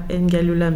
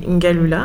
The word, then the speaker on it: Ngalula: Euh, bah, si je peux me permettre, déjà mon nom c'est pas Ngalula mais Ngalula.